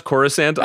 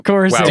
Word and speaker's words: Coruscant: Wait, he's going to say Corsica. Did you just mispronounce Coruscant on a Star Wars and Jace podcast? – Wow. Coruscant.